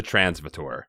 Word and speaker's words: transvator: You and Jamie decide to use the transvator. How did you say it? – Transvator? – Transvator. I'm going to go with transvator. – Like Darth Vader.